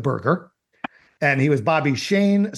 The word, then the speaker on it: Schoen: And he spells his last name S-C-H-O-E-N. But I believe it was still pronounced Bobby Shane. His real name, of course, was Schoenberger, S-C-H-O-E-N-B-E-R-G-E-R.